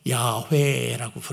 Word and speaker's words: 야훼: 근데 LORD는 여호와인데 이게 본래 발음을 어떻게 해야 되는가 하면 야훼라고 부르는 그러면서 만군의 하나님이라는 말은 이 여호와 하나님이 전능하신 하나님이다라고 하는 거예요.